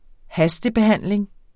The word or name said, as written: hastebehandling